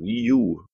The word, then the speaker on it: Wii U: die sie mit ihrem Wii U, glaube ich, spricht man es aus, oder, Boris? So habe ich das gehört, Wii U.